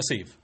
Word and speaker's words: sieve: How do you pronounce that? Sieve? – Sieve? – Sieve? – Sev. – Sev bleeds like a Sev. – A Sieve. No, you had it right.